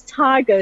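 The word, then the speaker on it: Tyga: And the American rap star, I'm not sure if it's pronounced Tiger or Tiger. I'm not 100% sure. He's the latest artist to announce that he will perform in Israel this coming summer. So it looks like Israel's getting ready for obviously quite a summer and planning it. When will he be performing? How do you pronounce his name? And who else will be rocking the summer? Who else is planned? – Well, I think it's Tiger.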